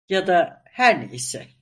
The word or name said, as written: neyse